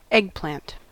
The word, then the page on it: eggplant: /ˈɛɡ.plænt/